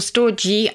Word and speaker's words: storge: All the possibilities of love can be summed up in storge. Is that how you pronounce it? – Yes, or storge. I think it's soft or hard G.